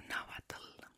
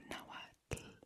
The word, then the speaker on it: Nahuatl: So Nahuatl has a very common noise in the language, which is TL. Pronounce it like t-t-t, like that. T-t. So Nahuatl, Iztaccíhuatl, Popocatépetl.